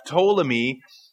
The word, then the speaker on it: Ptolemy: And then fourth is Ptolemy, but it's spelled with a P in front, Ptolemy, and Ptolemy controlled Egypt, the land of Judah, and Arabia Petraea.